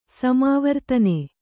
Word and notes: ಸಮಾವರ್ತನ: samāvartane; kannada

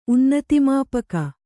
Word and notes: ಉನ್ನತಿಮಾಪಕ: unnatimāpaka

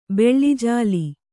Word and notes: ಬೆಳ್ಳಿಜಾಲಿ: beḷḷi jāli

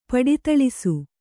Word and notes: ಪಡಿತಳಿಸು: paḍi taḷisu

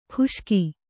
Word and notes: ಖುಷ್ಕಿ: khuṣki

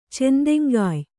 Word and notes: ಚೆಂದೆಂಗಾಯ್: kannada

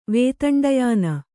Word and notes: ವೇತಂಡಯಾನ: Kannada

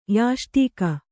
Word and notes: ಯಷ್ಟಿಕೆ: Kannada

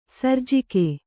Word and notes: ಸರ್ಜಕ: Kannada

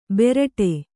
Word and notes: ಬೆರಟೆ: beraṭe

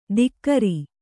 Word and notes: ದಿಕ್ಕರಿ: dikkari

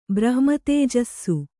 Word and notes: ಬ್ರಹ್ಮತೇಜಸ್ಸು: brahma tējassu

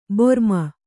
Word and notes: ಭ್ರಮಿ: bhrami

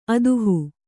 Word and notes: ಅದುಹು: aduhu

